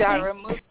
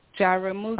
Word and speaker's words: Jaramogi: How do you say your last Jaramogi. – Name? – Jaramogi. – Oh, I really? Jaramogi. Yeah, I didn't say it right.